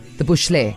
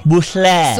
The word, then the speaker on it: Booshle: Gentleman has literally, he has made his name yeah. – phonetical so that we'll actually be able to spell it. So B-O-O-S-H-L-E.